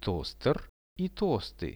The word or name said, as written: toster i tosty